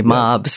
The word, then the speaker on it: mobs: I mentioned in that essay, mobs, yes. Rhymes with sobs.